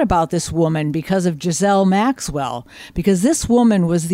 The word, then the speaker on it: Ghislaine: I know, but it's Ghislaine. It's like Elaine with a G on the beginning of it.